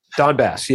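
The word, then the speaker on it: Donbas: I mean, we're talking about the Soviet Union earlier, and a lot of these states, and regions, I don't remember how it's pronounced other than it's close to dumbass, that region of Ukraine. – Donbass, yeah.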